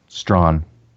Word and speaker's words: Strachan: Uh, the first one is from the Center for Public Theology at Midwestern Seminary, and uh, the author is I never know how to pronounce his last name Owen Strachan or Owen Strahan or Strahan. – Strahan. Okay, so none of the above.